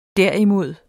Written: derimod

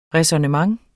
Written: ræsonnement

